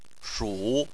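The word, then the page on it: shu: tone 3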